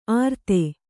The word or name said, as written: ಆರ್ತೆ